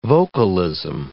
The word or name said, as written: vocalism